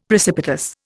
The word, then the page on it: precipitous: Indian